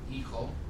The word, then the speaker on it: hijo: The word for son is this word, pronounced hijo, hijo. The word for leaf is this word, H-O-J-A, pronounced hoja, hoja.